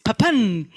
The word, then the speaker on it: Papin: Papán, no pronuncio muy bien el francés, pero ahí está.